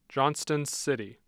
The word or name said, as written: Johnston City